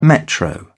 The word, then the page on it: metro: British English